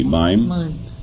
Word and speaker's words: mime: I am Roman, a mim, or a mime, how do you pronounce it, M-I-M-E, mime? – Mime, and dancer.